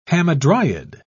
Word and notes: hamadryad: British English

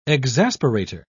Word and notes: exasperater: British English

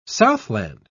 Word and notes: southland: British English

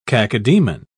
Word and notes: cacodemon: British English

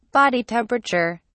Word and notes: body temperature: British English

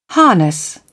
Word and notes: harness: British English

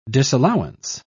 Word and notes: disallowance: British English

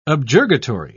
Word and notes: objurgatory: British English

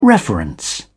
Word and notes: reference: British English

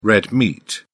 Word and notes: red meat: British English

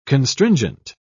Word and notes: constringent: British English

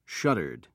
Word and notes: shuttered: British English

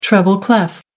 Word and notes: treble clef: American English

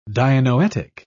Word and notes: dianoetic: British English

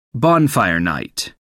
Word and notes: bonfire night: American English

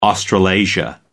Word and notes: australasia: American English

